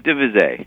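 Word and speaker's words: de Visé: Is that the right way to pronounce it, Daniel? – Oh, DeVise. – DeVise. I am sorry.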